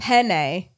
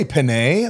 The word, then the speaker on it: penne: You're saying it like P-I-N-A-Y. – Oh, right. Because penne. E-N. Okay, people in Texas, like it's like Ben or a pen. Like oh, yeah.